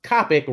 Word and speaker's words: Coppock: And us from me and Miss Coppock rhymes with topic to finally meet.